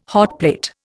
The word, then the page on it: hotplate: Indian